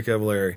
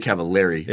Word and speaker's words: Cavallari: Recently she had a show called, is it Very Cavallari? – Yeah. – Is my saying that right? – Very Cavallari. – Yeah, Very Cavallari.